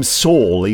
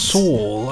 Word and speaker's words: Sewell: Well, no, he pronounces his name Saul, even though it's spelt Sewell. But Saul?